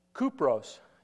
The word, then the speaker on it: Kupros: If you look, Cyprus is how we pronounce it today, but if you're Greek, you call it Kupros. And if you're Latin, it's the same language, but a little bit different. Cuprus, and those mean copper.